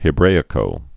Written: anno Hebraico

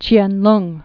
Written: Ch'ien-lung

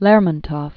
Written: Lermontov, Mikhail Yurievich

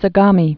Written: Sagami Sea

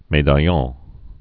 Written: medaillon

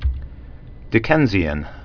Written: Dickensian

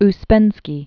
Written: Peter Demianovich Ouspensky

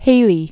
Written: Haley

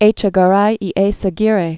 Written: Echegaray y Eizaguirre

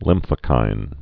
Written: lymphokines

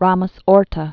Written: Ramos-Horta

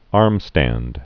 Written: armstand

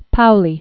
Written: Wolfgang Pauli

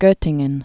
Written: Gottingen

